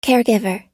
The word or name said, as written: caregiver